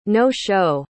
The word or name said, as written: no-show